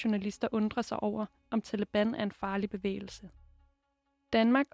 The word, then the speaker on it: Taliban: De kalder sig for de studerende af Koranen på pashto udtales det Taliban.